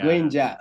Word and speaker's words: Granger: Paul Granger, did I pronounce it well? – Granger, yeah. – Granger, all right, I tried a bit.